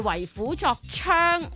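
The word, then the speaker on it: 為虎作倀: 其中一個智囊團剛才是不是讀了為虎作伥的、其實它的正確讀音是為虎作槍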